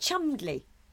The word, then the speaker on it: Cholmondeley: Is it Chumdley? – It probably is, actually.